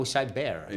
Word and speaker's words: Cybear: The company is called Cybear? – Cyber with a rawr. – Oh, Cybear. – Yeah, you spell it like a bear.